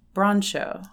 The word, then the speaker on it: Broncho: Performers include, and it looks like the word bronco, but I understand it's pronounced broncho.